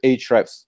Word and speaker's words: Ahrefs: But yeah, I think that from the grammatical or should I say lexical standpoint, the right pronunciation is Ahrefs, as far as I can tell. But we here, like in the Ahrefs headquarters, we just skip the other letter and call it Ahrefs because it's just easier.